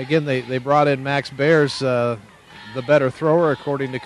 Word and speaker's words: Berres: His last name is spelled B-E-R-R-E-S, but Coach Brown called him Bears.